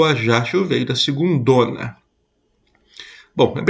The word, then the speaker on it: Ajaccio: Como é que fala esse nome aí? Ajaccio? Ajaccio.